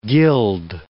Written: gild

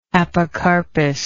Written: apocarpous

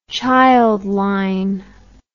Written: Childline

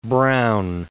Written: Browne

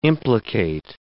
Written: implicate

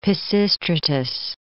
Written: Pisistratus

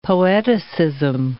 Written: poeticism

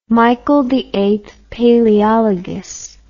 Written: Michael VIII Palaeologus

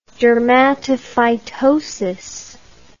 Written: dermatophytosis

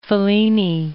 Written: Fellini

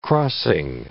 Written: crossing

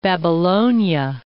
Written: Babylonia

